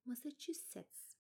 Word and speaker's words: Massachusetts: Hala daha çok farklı değil ama Massachusetts Üniversitesi'nin bu bence İngilizce'deki telaffuzu en zor kelimelerden biri. Bir daha deneyeceğim. Massachusetts. Tamam şimdi oldu.